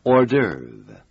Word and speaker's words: hors d'oeuvre: It is spelled H-O-R-S and D apostrophe O-E-U-V-R-E. It's pronounced hors d'oeuvre. – 大家一定感到这个字的拼法很奇怪。是的，这个字本身是法文，所以它的拼法和一般英文拼法不太一样。我们再 给大家拼一次。 – Hors d'oeuvre. H-O-R-S. And the last part is spelled D apostrophe O-E-U-V-R-E.